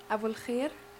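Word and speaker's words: Abouelkheir: Abu Al-Khair.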